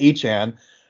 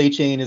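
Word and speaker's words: Achane: And that's Devon Achan. I guess he pronounces it Achan. – I don't know. – That's what he said. – I saw. – Even though he's been called Achan his entire college career and NFL career so far, it's now Achan after a big, three touchdown game, I think it was.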